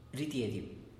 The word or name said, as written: ridiediu